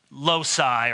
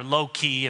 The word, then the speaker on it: loci: You know, in systematic theology, you have different uh, loci or loci, if you were really pronouncing the Latin correctly, different themes, categories.